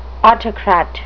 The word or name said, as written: autocrat